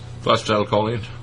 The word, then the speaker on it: phosphatidylcholine: And she was saying that if you take cod liver oil, that you should take a phosphatidylcholine. How do you say that? – Phosphatidylcholine.